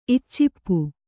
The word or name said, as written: ಇಚ್ಚಿಪ್ಪು